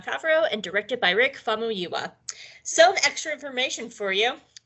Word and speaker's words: Famuyiwa: It aired on November 15th, 2019, and it was written by Jon Favreau and directed by Rick Famuyiwa. Nah, that's not right. Let me do that one again. Famuyiwa. It's Famuyiwa, right? Famuyiwa. – That's the only way I can think of to pronounce it.